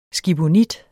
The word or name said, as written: skibonit